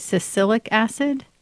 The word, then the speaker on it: Salicylic acid: Salicylic acid? – Yes.